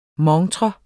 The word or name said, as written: MONTREN